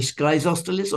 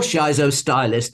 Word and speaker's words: Schizostylis: It used to be Schizostalis or Schizostalis or Schizostylis, depending on your pronunciation. It doesn't matter.